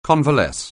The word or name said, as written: convalesce